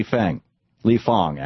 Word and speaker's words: Fang: Journalists funded by vulture capitalist Paul Singer, campaign to smear Wall Street protests by Li Fong, actually is how it's pronounced. Spelled F-A-N-G.